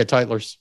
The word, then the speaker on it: Titleist: I mean, I think that's how you say it, Titleist. – Yeah, Titleist.